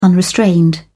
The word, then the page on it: unrestrained: British English